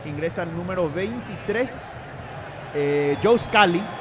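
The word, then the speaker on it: Scally: Sí, parecido, solo que con A en vez de U en este caso. Pero bueno, se pronuncia igual, Scully.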